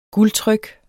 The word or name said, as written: guldtryk